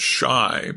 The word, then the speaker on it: Chi: Chi, pronounced shy, by the way, is the argot for Chicago.